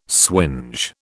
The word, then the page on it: swinge: British English